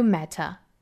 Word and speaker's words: matter: Ever tried Ever failed, no matter, try again, fail again, fail better. 下面我们看美式发音呢。 Ever 这个词呢，美音就要发成了 ever 了。 Ever tried, ever failed, no matter. 这个 matter, 我们注意它美式发音呢，应该把它读成一个 flat T. Matter, 舌尖的位置在硬腭一弹而过。 Matter, 碰一下就去卷舌了。